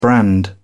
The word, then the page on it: brand: British English